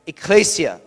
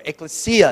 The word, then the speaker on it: ekklesia: Ecclesia, or ekklesia, depending on the pronunciation, in Greek is simply the word for church.